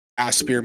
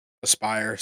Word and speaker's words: Aspyr: Aspyr, sorry. – I feel like we had that conversation before. Like, it looks like Aspyr, and I feel like it should be pronounced that way. But regardless, Aspyr.